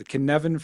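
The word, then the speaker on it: Cynefin: Is it the Kinevan framework? All right. – You get an A.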